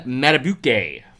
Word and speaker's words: Madubuike: Justin Madubuike, from Texas A&M. You said it right.